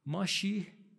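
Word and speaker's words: ماشیح (משיח): از حال رو بعدن میگم، مسیح تلفظ یهودیش ماشیه، ماشیح مسیح مهدی.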